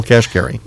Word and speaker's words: Kashkari: Neil Kashkari.